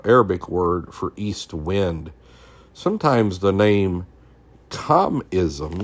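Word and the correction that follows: khamsin: let me make sure I'm saying that right. Qamsin, Qamsin, Qamsin, Qamsin is applied to them.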